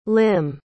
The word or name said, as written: lim